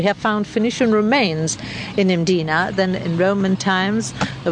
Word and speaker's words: Mdina: She wanted to take me to the classic city of Mdina. There's no vowel in that first syllable. It's just M-D-I-N-A.